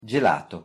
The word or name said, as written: gelato